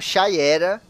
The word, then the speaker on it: Shiera: Aliás, as duas são Shayera, mas a primeira se escreve Shiera, que é essa da década de 40, que começou, né?